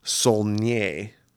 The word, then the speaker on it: Saulnier: It's called Green Room, written and directed by Jeremy Saulnier. That's how you pronounce it.